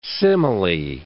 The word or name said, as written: simile